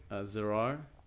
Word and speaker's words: Zirar: I'd like to say that our brother Zirar, is that how you pronounce it? Zirar? – Yeah, that's right.